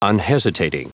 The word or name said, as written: unhesitating